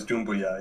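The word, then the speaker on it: Doumbouya: So we talked about the main pronunciation is Dumboya.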